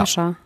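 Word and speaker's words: Kasia: Natürlich geht es um Kascha. Also wird die ausgesprochen, ne? – Ja. – Kascha.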